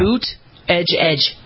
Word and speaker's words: Buttigieg: Boot, edge, edge.